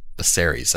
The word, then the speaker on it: Viserys: Viserys, that's how you say it.